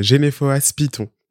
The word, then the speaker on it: Genefohas Piton: c'est Genefoas Python Python